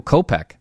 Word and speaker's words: Kopech: The Sox have been looking good, though, especially rookie Michael Kopech, the hard-throwing righty with the hard C sound at the end of his name, even though it's spelled C-H.